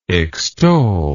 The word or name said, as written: extollment